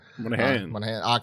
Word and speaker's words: Monaghan: Monaghan? Is that how you say his name? Yeah. – Monaghan.